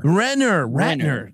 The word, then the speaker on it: Renner: Jeremy Renner. – Renner.